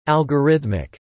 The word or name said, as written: algorithmic